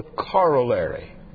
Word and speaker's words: corollary: You Americans all butcher it. The corollary, you will call it the corollary. The last time I looked at it in English, there were two L's in the middle, and that's corollary.